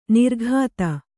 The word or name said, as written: ನೀರೋಗತೆ